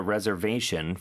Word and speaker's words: reservation: Reservation. 자, 강세 들리셨죠? 그리고 S 발음은 Z 발음으로 reservation.